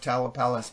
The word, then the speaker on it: Tamalpais: Tamalpais Exchange. Do you know of the Tamalpais Mountain? – I found it when I looked it up on, was looking them up. It came up Tamalpais Mountain.